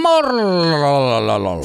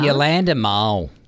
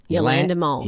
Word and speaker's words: Yolanda Moll: Morlololol. – Stop. Say it properly. – Yolanda. – No. Stop it. – It's fun. Yolanda. Yolanda Moll. – Okay. – Literally M-O-L-L is her surname. – Yep. Yolanda Moll.